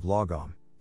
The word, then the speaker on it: lagom: The Swedish concept of lagom, pronounced lagom, roughly translates to not too little, not too much, just right.